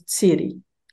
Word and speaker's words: tsiri: So if you take the word for corn, a very important word in Purépecha and across all Mesoamerican societies, the word for corn in modern day Purépecha is tsiri, so with the stress on the first syllable.